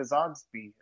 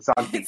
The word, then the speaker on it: Zogby: Just just like going back to Zogby. I just said his name wrong. Zogby.